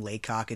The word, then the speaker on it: Laycock: This doll, according to the State of Texas University professor Joseph Laycock, yes, that's his name, Laycock, it's spelled L-A-Y-C-O-C-K,